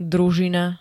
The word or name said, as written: družina